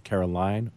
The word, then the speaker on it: Caroline: And there's, there's one small bone I have to pick if your name is spelled C A R O L I N E, I think it's Caroline, but I have been sometimes corrected to Carolyn. So I'm going to ask you, Ms. Stokes, is the correct pronunciation of your name Caroline or Carolyn? Caroline is 100% perfect.